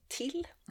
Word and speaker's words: Tyll: Men då vill jag nog faktiskt välja att prata om Daniel Kehlmans fantastiska picaresk roman Till, tänker jag att man ska säga, för titeln stavas T-Y-L-L, men det är ett tyskt namn, så det ska tydligen uttalas Till.